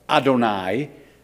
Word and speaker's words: Adonai: Mercy is represented by the divine name Yod-Heh-Vav-Heh, pronounced as Adonai, translated as the Lord.